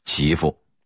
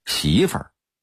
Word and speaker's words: 媳妇: 媳妇，普通话应该念成媳妇，但北京土话的发音是媳妇儿。